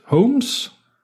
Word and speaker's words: Holmes: Holmes, som udtales Holmes, eller på dansk hjem, kan hænge lidt sammen med House.